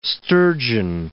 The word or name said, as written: sturgeon